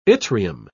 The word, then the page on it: yttrium: British English